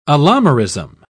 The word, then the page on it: allomerism: British English